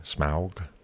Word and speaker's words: Smaug: That's the way you have to pronounce it, by the way, Smaug.